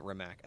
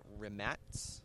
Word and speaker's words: Rimac: Or I try to pronounce the everyone pronounces it Rimac. Apparently it's like Rimats.